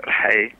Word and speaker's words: Rhae: And that has the second two words there, Rhae, is R-H-A-E, and I just left the R and the H as they were, so you pronounce both in order.